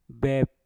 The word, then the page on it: bep: mpur